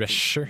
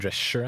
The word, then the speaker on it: Drescher: Uh, eh, og um, har Fran Dretcher Jeg er litt usikker på hva jeg Drescher. – Drescher, Er det sånn man uttaler altså? – Jeg tror det er Drescher.